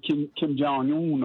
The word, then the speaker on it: Kim Jong-un: But I think Mr. Kim Jong-un, or whatever his name is, if I maybe pronounce it wrong.